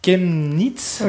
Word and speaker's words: Chemnitz: C'est Chemnitz, oui, c'est ça.